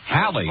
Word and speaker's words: Halley: Well, now, Halley, as far as the name is concerned, I'm some of the best biographies that have come out of England in recent years say that it rhymes with valley.